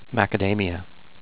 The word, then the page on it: Macadamia: mac-uh-DAME-ee-uh